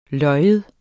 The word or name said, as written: lyv